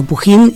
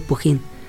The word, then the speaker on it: Pugín: no tengo, tengo, tengo dos apellidos, tengo Pujín y tengo Puyén Y hay un tercero que es Pujon o algo así. – Oh, qué fino. – Eh, pero entonces Pujín como se escribe según el ánimo. – Según, las personas. por lo menos. Sí, porque se escribe así. se escribe Pujín con G, así que es Pujín.